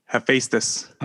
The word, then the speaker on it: Hephaestus: This week, this time, we're talking about Iceburn's double LP, Hephaestus.